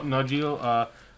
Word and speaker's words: Nanjiani: With Kumail Nanjiani.